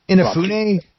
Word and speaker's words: Inafune: I think it's it'll be Inafune. – Yeah, that works. – Yeah. All right, whatever. Okay, so Keiji Inafune has announced he was fired from Capcom years ago, very famously, just kicked to the curb.